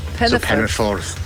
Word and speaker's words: Penyffordd: Okay, so, when you have the two D's, it's pronounced like a TH? – It's pronounced like, yeah, so it's of the. So, two D's is of the. – So, Penyffordd.